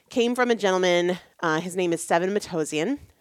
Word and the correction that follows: Sevan Matossian: uh, Sevan, actually, I think is how you pronounce it, Sevan Matossian.